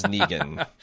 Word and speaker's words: Negan: They're pronouncing Negan as Negan. – Okay. – You know, I mean, to be fair, he did create the character. He did name the character. – Sure, but we also speak an English language where, you know, we have words that have the knee sound, like K-N-E-E or N-E-E. Mm-hmm. Usually N-E-G is pronounced Neg, and There's no diachrony.